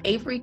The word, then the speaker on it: Averri: So, Avery comes from the root word Avery, and of course the usual spelling is A-V-E-R-Y, but my mom decided to spell it A-V-E-R-R-I so that no one would mispronounce my name.